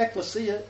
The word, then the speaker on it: ecclesia: Did I say ecclesia? Yeah, not ecclesia. Get it right. Ecclesia. Everybody say ecclesia. – Ecclesia. – Okay, do not forget it. That's how you pronounce it. Not ecclesia. Ecclesia.